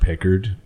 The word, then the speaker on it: Picard: It's pronounced Pickard.